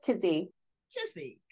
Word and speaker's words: Keesee: That's Keesee.